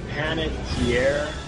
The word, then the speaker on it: Panettiere: I can never pronounce this name right. Hayden Panettiere? – Yeah, you got it right.